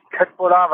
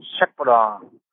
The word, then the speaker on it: Köttbullar: Das heißt nicht Köttbullar, weil es ja nicht korrekt ausgesprochen ist, sondern Schöttbullar.